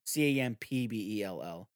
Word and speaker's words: Campbell: You spell Campbell like the soup, CAMPBELL.